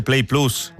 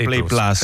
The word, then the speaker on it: plus: Quindi quando diciamo anche la nostra app Play Plus, si dice Play Plus, non Play Plus. – Play Plus. – Non Play Plus. – Play Plus. – Play Plus.